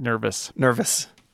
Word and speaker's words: NIRVIS: It's not spelling an actual word, and then you pronounce it like a word, so I'll go with it. NIRVIS.